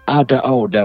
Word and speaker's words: Ada Oda: Sì, poi la pronuncia forse dovrebbe essere Ada Oda, perché si riferisce a un'espressione dello slang italo-americano. Quindi un'Ada Oda sarebbe un'altra volta, cioè another, no?